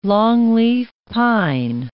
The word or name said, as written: longleaf pine